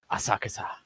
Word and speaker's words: Asakusa: Just the beautiful animation and depiction of this fantasy world that our main character, Midori Asakusa, which is a very fun name to say when you say it like the character said, Asakusa.